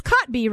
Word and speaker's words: Kotb: It's Kotb, right? I'm saying that right, Kathy Lee? – Yes, yeah.